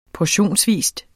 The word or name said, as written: portionsvis